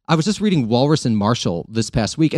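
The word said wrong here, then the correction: Walras: and they're like, Valra.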